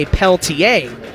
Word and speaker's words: Peltier: It's spelled P-E-L-T-I-E-R. The French might say Peltier. He pronounces Peltier.